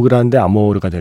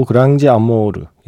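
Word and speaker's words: Grande Amor: 스페인어식으로 발음하면 오 그란데 아모르가 될 텐데 브라질어로 하면 오 그랑지 아모르.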